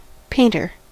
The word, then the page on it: painter: American English